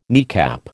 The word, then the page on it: kneecap: American English